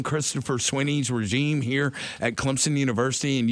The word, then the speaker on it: Clemson: People that live here know when you say that school 30 minutes down the road, there's an invisible P in the pronunciation. You don't say Clemson. You say Clemson.